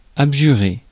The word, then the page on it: abjurer: [ab.ʒy.ʁe]; France